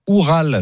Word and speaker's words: Oural: Le Zénith Saint-Pétersbourg qui a étrié Oural, je pense que ça se prononce comme ça. – C'est ça.